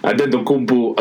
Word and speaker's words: Antetokounmpo: I would love to see Antetokounmpo, I said it correctly, Antetokounmpo against LeBron and see how that plays out.